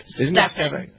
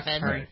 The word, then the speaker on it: Stephen: Is it Stefan? It's Steph. – Stefan. – Yeah, Stefan. – I pronounce it with an E.